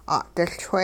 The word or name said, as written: 'a'diLchwe